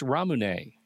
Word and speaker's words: Ramune: It's Ramune.